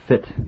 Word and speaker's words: fytte: It's called FITT, but it's spelled F-Y-T-T-E, which is, I guess, a portion of a poem, right? – Yes, it is a section of a poem.